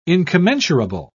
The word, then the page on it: incommensurable: British English